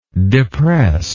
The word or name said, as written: depressed